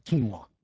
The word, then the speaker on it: quinoa: No, it's called quinoa. Quinoa. That's the way you pronounce it.